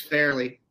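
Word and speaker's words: Fairleigh: Well, so, I think Farley Dickinson, and I think I mispronounced that. – It's Fairley.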